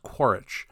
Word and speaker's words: Quaritch: Miles Quaritch.